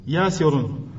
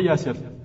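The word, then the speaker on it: يَاسِرٌ: À la base, Yasirun, صح? Tu vas appeler Yasir. Yasirun. – Et tu le prononces quand tu l'appelles? – Yasir. De toute façon, Aslan, les Arabes, dès qu'ils s'arrêtent, ils ne prononcent pas la dernière moyenne. – Ouais. – Mais normalement, c'est comme ça. Yasirun.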